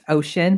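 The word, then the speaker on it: Oshun: I think it's pronounced ocean, but it's O-S-H-U-N.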